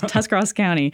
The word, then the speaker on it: Tuscarawas: It's Tuscarawas County.